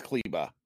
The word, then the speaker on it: Kleber: and you have a situation where how do you pronounce it? Kleba? – Yeah. Yeah. – It's Kleba. – Looks like Kleber, but it's Kleba.